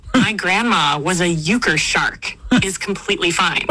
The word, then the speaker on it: euchre: Euchre, pronounced euchre, is simply the name of the card game.